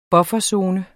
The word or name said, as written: bufferzone